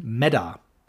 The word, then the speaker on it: Meta: Schreiben tut man es Meta, aussprechen Meda.